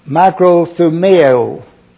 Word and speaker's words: makrothumeo: If you'll notice, the first word in the Greek, it is makrothumeo. Makrothumeo. It's spelled M-A-K-R-O-T-H-U-M-E-O.